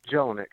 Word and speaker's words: Jelinek: But Jelinek. – Who? – What, you Jelinek. Can't, Jelinek, you can't spell that, Dino? – Chilinek? – Jill, J Jelinek, with a J. – J-E-L-E-N-E-K. – N-E-K.